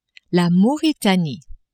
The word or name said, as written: Mauritanie